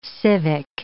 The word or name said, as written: civic